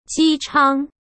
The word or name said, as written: Ji Chang